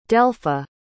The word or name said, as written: delpha